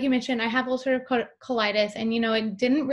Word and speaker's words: ulcerative colitis: So I would love to kind of touch on, you have a chronic illness, ulcerative colitis.